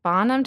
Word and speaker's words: Bonham: Bonham, did I say that right? – Yeah.